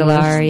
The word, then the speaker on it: Zandalar: Zandalai, I guess is how it's pronounced. – Zandalar, yeah.